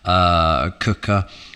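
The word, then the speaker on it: Quooker: But can I just say this podcast is sponsored by Cooker. Spelt quicker, pronounced cooker, 100 degree boiling hot water straight from your kitchen tap.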